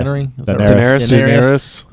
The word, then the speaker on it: Daenerys: We're loving Tyrion and Dannery. – Yeah. – Daenerys. – Yeah, I knew I wasn't saying that right.